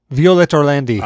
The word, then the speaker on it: Orlandi: So the Queen Bohemian Rhapsody cover, it's by Halo Scene, First to 11, Violent Orlandi, and Violet Orlando. Violet Orlandi.